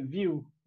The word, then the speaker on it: viu: True or false, you sometimes end your sentences with view, V-I-U. – Yeah. – How do you pronounce that? – View. It's pronounced view. It's pronounced similarly to the way you guys would pronounce V-I-E-W.